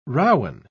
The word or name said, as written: rowan